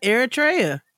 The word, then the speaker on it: Eritrea: It is Eritrea.